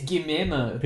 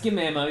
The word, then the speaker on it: Guillermo: Yeah, it's Guillermo. – People make fun of me when Guillermo. – I can't pronounce his name. – No, it's Guillermo, you're right.